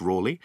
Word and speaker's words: Ralegh: I'm going to talk about Walter Raleigh, and it's pronounced Raleigh, and the myth of him laying down his cloak for Elizabeth I in his puddle.